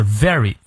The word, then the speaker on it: very: Optimism is a very v 的发音啊，上排牙齿摩擦下排嘴唇。